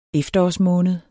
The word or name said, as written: efterårsmåned